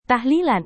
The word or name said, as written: tahlilan